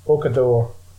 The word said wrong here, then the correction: Okafor: Is it on the wing? – Jaden Okafor.